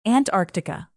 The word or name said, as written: antarctica